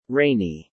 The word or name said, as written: rainy